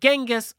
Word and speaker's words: Genghis: I have heard Chinggis, I have heard Genghis, I have heard Chinggis. I like Chinggis.